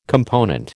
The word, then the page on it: component: American English